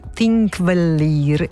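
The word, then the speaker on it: Þingvellir: Si trova nel parco nazionale, attenzione alla pronuncia, di Thingvellir in Islanda, a circa 50 km da Reykjavik, che è la capitale.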